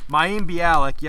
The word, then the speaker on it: Mayim Bialik: Mayim Bialik, yep, she was Amy in The Big Bang Theory.